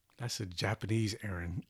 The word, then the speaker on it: Erin: It's Aaron, E-R-I-N, not Irwin, E-R-I-N.